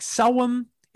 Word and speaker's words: Samhain: it's spelled out Samhain. I know it's like Selwyn is how it's actually pronounced.